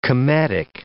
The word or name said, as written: cometic